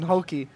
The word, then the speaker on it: Hulk: Hulk. That's the Portuguese pronunciation, Hulky.